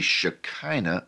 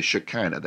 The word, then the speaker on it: Shekinah: he said that it was most likely the Shekinah of God. The Shekinah of God. Now that's spelled S-H-E-K-I-N-A-H, although it's spelled in different ways because there's no particular accepted spelling of it.